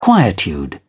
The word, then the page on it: quietude: British English